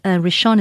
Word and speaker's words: Rishon: It's spelt, Rishon is R-I-S-H-O-N. Rishon, and Naphtali is N A P-H, P for Peter. So it's Naphtali, N-A-P-H-T-A-L-I.